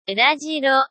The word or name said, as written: urajiro